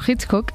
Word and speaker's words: Hitchcock: Claro, Hitchcock.